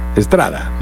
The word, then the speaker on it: Estrada: Michael Astrada. Estrada. – Estrada, sí. Michael Estrada. No se escribe igual, ¿no? No se escribe igual, es con E en el caso de del apellido del jugador, pero suena igual.